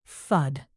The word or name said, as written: thud